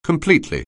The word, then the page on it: completely: anglais